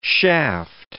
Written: shaft

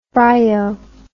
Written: bryo

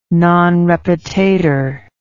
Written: non repetatur

